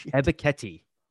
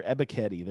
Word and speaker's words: Ebiketie: How do you say his last name? – Ebiketie. – Thank you. I can never. Ebiketie.